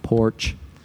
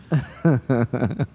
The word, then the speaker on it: Porsche: It's yeah, pronounced Porsche. Porsche. Not porch, Porsche. Porsche.